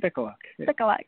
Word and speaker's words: Spicoluk: Spicoluk.